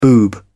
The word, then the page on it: boob: British English